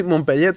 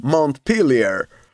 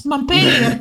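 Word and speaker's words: Montpelier: Στο μεταξύ, Μομπελιέ το λέω εγώ γιατί είναι το, το Μομπελιέ. Εκεί πέρα μπορεί να το προφέρανε Mount Pillier. – Ναι, ε, έτσι το λένε, δυστυχώ. Μαμπέλιερ το λένε.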